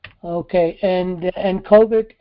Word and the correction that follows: COVID: is the COVET, I think, or? – Oh, it's COVID. – COVID, COVID.